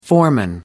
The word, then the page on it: foreman: American English